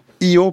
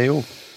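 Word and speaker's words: EO: Donc cet âne, il faut dire I.O. pas E.O.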